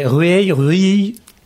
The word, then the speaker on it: Rueil: C'est Rueil.